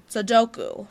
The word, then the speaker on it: Sudoku: It ends with oo. – Sudoku.